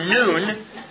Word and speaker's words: nun: Actually, pronounced in the Hebrew, nun, the letter N-U-N, is missing from the psalm.